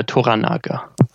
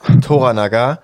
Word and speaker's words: Toranaga: Wie spricht man ihn aus? – Muss ich jetzt gerade mal. Toranaga. – Toranaga.